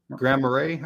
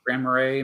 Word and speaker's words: Grand Marais: Uh, nice shirt and hi from Grand Morass. Is it Grand? How do you pronounce that? – Grand Marais, I think. Grand Marais, Minnesota, just south.